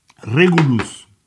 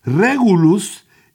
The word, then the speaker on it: Regulus: Regulus. Es que se perdona pero es eh, latimba, te tengo que decir cómo suena, vos lo pronuncias en inglés. Regulus y, regulus es una palabra que viene del latín rex, que es rey.